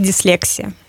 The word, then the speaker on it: дислексия: Правильно говорить, и мы считаем, мы для себя решили, что мы будем говорить «дислексия».